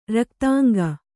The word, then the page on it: ರಕ್ತಾಂಗಿ: raktāngi